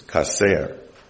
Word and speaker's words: chacer: The transliterated word for lack or want, because it's the same word, is kaser. It's spelled C-H-A-C-E-R. And it means to have no need,